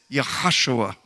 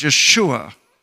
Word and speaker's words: Yeshua: And then I look at him and say, it says Yahshua, doesn't it? And they'll stare at me. There's a reason I'm saying that. Because the name Yahshua, it really is also pronounced Yeshua. Yeshua, Yahshua.